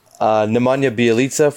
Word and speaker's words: Nemanja Bjelica: Nemanja Bjelica from the Kings, stretch four.